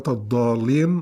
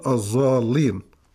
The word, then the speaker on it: الضالين: في مقدمة هذه الحلقة لدينا سؤال من الأخ أبو علي، يقول: سألني أحد الإخوة بأنه كان ينطق كلمة الضالين في الصلاة خطأ، وكان يقول الظالين. – نعم، يخلطها بالزاي يعني.